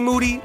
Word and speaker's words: Moody: G Moody, last name rhymes with Duty, a.k.a.